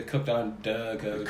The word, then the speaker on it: Cooked on Da Couch: We have got the guys from Cooked on a Coach here today. Am I saying that right? – Yeah, buddy. Cooked on Duh Coach.